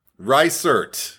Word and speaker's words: Reisert: Up next, I don't know how to pronounce her last name. – Reisert.